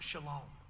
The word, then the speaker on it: shalom: There's a, a Hebrew word that is pronounced shalom.